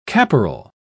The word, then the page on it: caporal: British English